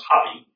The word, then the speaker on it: Hapi: This particular God, they call Him Happy. Pronounced Hapi, H-A-P-I.